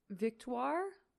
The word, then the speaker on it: Victoire: Just a short 8 years ago, devoted fans will recall Master Lupin was quite adamant about his now ex-girlfriend, the stunning Victoria. Is that how you pronounce that? – Which one? Victoire? – Victoire?